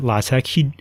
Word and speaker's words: LaTeX: He pronounced it LaTeX. He said, even though it looks like, sort of like the word latex, it's pronounced LaTeX.